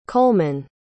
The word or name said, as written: coleman